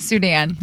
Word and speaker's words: Sudan: Sudan, yep.